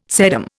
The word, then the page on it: sedum: Indian